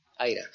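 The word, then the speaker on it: IHRA: ¿En español se dice AIRA o IRA? Bueno, AIRA. Que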